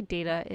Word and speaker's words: data: According to the Cambridge Dictionary and Merriam-Webster, the correct way to say data is day, D-A-Y, ta, T-U-H.